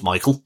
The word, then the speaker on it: Miquel: For this Canadian singer and actress, Miquel, or pronounced Michael, if you will, Brown, who is Sunita's mum.